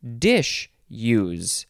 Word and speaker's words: disuse: D-I-S-U-S-E. This is the first form, and again, you can pronounce it dish-use. Dish-use.